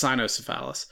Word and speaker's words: cynocephalus: The canocephalus. Well, I guess canocephalus, because canocephalus puts a little bit too much emphasis on phallus. It might also be pronounced cynocephalus.